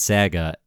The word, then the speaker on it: Saga: Well, because some people say it's saga, and like, I'm like, is that like an accent thing, or is that like, this one's pronounced saga, and because I just call it saga. – Yeah, of course. It's saga.